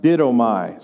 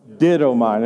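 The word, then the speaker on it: didomai (δίδωμι): If you have your Bible, if you'll look at verse 18, there is a word there, and it's pronounced didomai. Say that with me, didomai. Now let's all say it, didomai.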